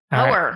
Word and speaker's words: Poer: P-O-E-R. Power. Right. – Like poet, but with an R.